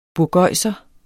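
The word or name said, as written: BURGØJSERE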